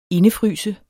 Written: indefryse

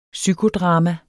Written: psykodrama

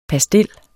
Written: PASTILLENS

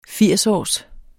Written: firsårs

